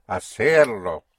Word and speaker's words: hacerlo: Hacerlo. – Yes, but don't give me two pushes. Only one. – Hacerlo. – Hacerlo. Right. Hacerlo. Yes. So hacer, and you hook on the lo to the R. Hacerlo.